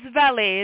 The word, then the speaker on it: Valli: Well, it's Valli. It is a French name, but I will take it the way you said it.